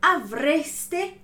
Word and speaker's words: avreste: Então, mais uma vez, a vogal E bem pronunciada para não se confundir ali com o tu, avresti, ok? Então, avreste avuto. Voi avreste avuto.